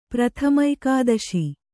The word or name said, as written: ಪ್ರಥಮೈಕಾದಶಿ